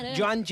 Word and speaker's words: Joan: Cas, se nom Joan, però se pronuncia Joan, i és una lota, xeram, de...